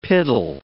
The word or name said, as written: piddle